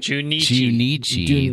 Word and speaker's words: Junichi: Depending on which Pikachu you choose, a different track created by Pokemon music producer Junichi? Did I say that right? – Junichi. – There's no extra N in there.